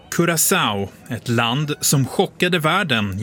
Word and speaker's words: Curaçao: Då vet ju du hur man uttalar landets namn. – Ja, man säger Curaçao. – Curaçao. – Ja, Curaçao.